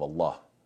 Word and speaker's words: Allah: And yet, subhanAllah, there's one exception. The one exception is the name of Allah. That's the only time that you, you pronounce the letter lam with a full mouth, uh, uh heavy ah sound.